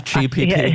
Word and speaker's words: ChatGPT: Is it ChatGPT? I don't use that. I never have. – ChatGPT.